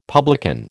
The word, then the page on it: publican: American English